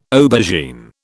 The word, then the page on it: aubergine: British English